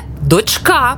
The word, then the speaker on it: дочка: Як правильно? Донька чи донька? Правильно, донька. Але дочка. Наголос на «а».